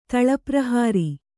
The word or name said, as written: ತಳಪ್ರಹಾರಿ